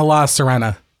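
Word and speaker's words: La Sirena: Back on the La Serena. Am I saying that right? – Yes.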